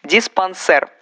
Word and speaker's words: диспансер: Диспансер. Никаких диспансеров. Ударение падает на последний слог и только на него.